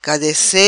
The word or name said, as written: Cadace